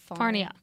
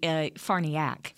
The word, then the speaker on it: Farniok: Matt Farniak. – It's actually Farniak. – Farniak. – It's with an O, Farniok, but it's pronounced Farniak.